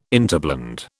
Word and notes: interblend: British English

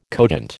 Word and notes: cogent: American English

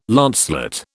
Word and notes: lancelet: British English

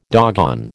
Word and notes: doggone: American English

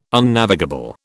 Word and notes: Unnavigable: British English

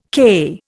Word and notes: k: Indian

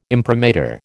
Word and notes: imprimatur: American English

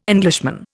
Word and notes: Englishman: Indian

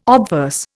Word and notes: obverse: Indian

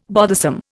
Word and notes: bothersome: Indian

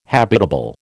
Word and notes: habitable: American English

